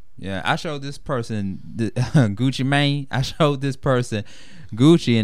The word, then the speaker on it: Gucci Mane: How he probably says it, but I probably say Gucci Mane. – Well, yeah, I mean, whatever. – I'm just saying it the way it's pronounced, you know, with the long A.